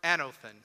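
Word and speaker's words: anothen (ἄνωθεν): You cannot see God's reality unless you are born anothen. Say that with me, anothen. It's a Greek word. It's spelled in English, it's like another, but it's got an N at the end.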